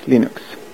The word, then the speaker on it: Linux: Hello, this is Linus Torvalds and I pronounce Linux as Linux.